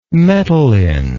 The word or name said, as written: metalline